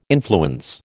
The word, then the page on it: influence: American English